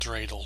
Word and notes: dreidel: American English

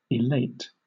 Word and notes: elate: British English

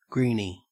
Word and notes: greenie: Australian English

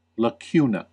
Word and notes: lacuna: American English

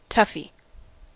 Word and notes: toughie: American English

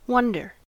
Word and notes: wonder: American English

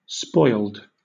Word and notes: spoiled: British English

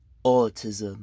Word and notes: autism: British English

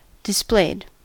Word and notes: displayed: American English

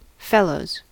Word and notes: fellows: American English

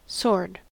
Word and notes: sword: American English

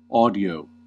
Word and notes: audio: American English

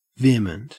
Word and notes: vehement: Australian English

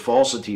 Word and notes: falsity: American English

